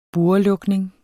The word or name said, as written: burrelukning